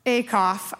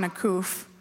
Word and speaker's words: Acuff: John Acuff, I said it right this hour. Last hour I said John Acuff.